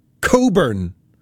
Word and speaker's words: Cockburn: It's of Scottish origin, and you pronounce it Coburn. However, it is spelt C-O-C-K-B-U-R-N.